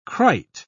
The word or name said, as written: krait